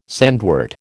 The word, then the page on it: sandwort: American English